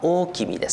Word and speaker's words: 大王: で後には天皇と呼ばれましたけども、その大和王権の、中心となったのが、まあ、漢字では大王と書きます。大王ですね、読み方は大君です、ね、大王、大きな王と書いてね、読み方は大君ですね、はい。